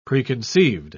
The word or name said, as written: preconceived